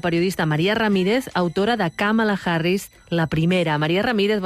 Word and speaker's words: Kamala: De mare índia, pare jamaicà, va haver d'explicar moltes vegades que el nom es pronunciava Kamala, que l'Índia és un nom molt comú i que vol dir flor de lotus.